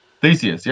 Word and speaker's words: Theseus: Well, now, the next one is called, I hope I pronounce it right, Theseus. – Theseus. Yep.